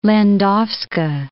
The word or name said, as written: Landowska